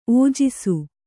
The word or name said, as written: ಔಜಸ